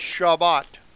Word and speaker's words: Shabbat: They pronounce it Sabbath. A lot of them do that don't understand the Hebrew, but it's Shabbat.